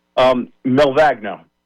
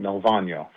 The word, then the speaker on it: Melvagno: Melvagno. Well, that's the Americanized way. In Italy, the G is silent. Melvagno.